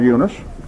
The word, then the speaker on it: Eunice: We'd call her Eunice.